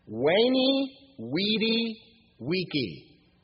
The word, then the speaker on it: Veni, vidi, vici: He said, "Veni, vidi, vici." I came, I saw, I conquered. You do pronounce the V with a W, right? No, you do. I looked. Veni, we, or okay, veni, vidi, vici.